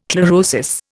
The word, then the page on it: chlorosis: Indian